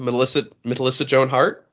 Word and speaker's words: Melissa Joan Hart: if he just got to be the random dude who popped up into Melissa Joan, can I say her name? Melissa Joan Hart? Is it Joan in the middle? – Yeah, you had it. Although strangely, you said Matissa.